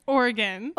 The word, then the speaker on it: Oregon: It's like okay. The organs in your body a little bit, Oregon. – Or an organ that you play. – You're from Oregon.